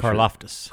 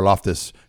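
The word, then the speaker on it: Karlaftis: They're going to see another elite All-American defensive end this week, and George Karloftis from I butchered his last name. I'm pretty Karloftis. – Sure. Karloftis from Purdue.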